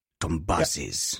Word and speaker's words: Tombazis: It's the FIA's head of single-seater matters, Nicholas Tombazis, who says, did I say that right? Tombazis. Yep.